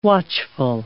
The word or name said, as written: watchful